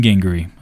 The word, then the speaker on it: Gingery: Stephen Gingery, obviously who this is for. G-I-N-G-E-R-Y. It's spelled gingery. If you're into like ginger ale.